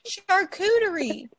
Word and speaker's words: charcuterie: Charcuterie. – Charcuterie. – No, it ain't coochie nothing. It's cooter. Charcuterie.